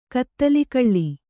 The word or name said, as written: ಕತ್ತಲಿಕಳ್ಳಿ